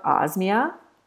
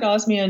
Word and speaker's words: Osmia: I'm speaking with the founder of Osmia.